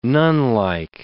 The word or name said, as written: nunlike